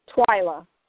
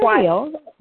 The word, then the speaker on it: Twylia: It's pronounced Twyla. – Twyla. Okay. – Twyla. Yes. – I just wanted to make sure I got that right. – Okay. Yeah, the I is silent.